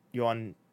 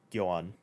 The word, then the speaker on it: Jhoan: That's spelled J-H-O-A-N, if anybody's curious. So Yoan, but not spelled like any other Yoan.